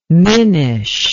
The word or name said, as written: minish